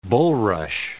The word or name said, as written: bulrush